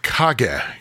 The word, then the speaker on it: Kage: Kage, which, by the way, is Japanese for shadow. – Ooh. – And the Legend of Kage. Yeah, we were mispronouncing it last episode, but yeah.